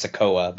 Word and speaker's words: Sikoa: Did I say it right? – I think it's Sikoa, but yeah, Sikoa. Solo Sikoa.